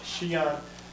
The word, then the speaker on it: Xi'an: If you have an X in Chinese, it's pronounced but it's Xi'an.